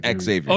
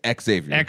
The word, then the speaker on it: Xavier: I say the letter and then I do the rest? – Yes, Xavier.